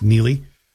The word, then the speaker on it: Neagley: Frances Neely, and by the way, if you're looking for it on Amazon, it's spelled N-E-A-G-L-E-Y. Sort of looks like Neagley, but it's pronounced Neely.